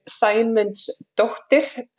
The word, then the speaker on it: Saemundsdottir: So my last name is Saemundsdottir, and how it actually works in Iceland, it's my dad's first name, and it's Dottir on the end means daughter.